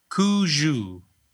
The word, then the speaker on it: cuju: C-U-J-U. Like Cujo with a U.